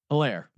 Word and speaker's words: Helaire: Moving over to the Kansas City Chiefs, they got Clyde Edwards-Helaire out of Hilary. How do you pronounce it? – Hilaire. – Yeah, it's French, bro.